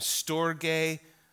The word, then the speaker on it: storge: So the first kind of love that we see in the Greek language is storge. I thought it was storge. I had to look it up yesterday on Google and tell me how to pronounce it. Storge.